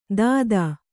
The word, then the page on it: ದಾದಾ: dādā